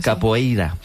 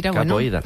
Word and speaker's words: capoeira: Pues todos sabemos lo que es la copeira, ¿verdad? Capoeira. Qué mal pronuncio últimamente. – Capoeira.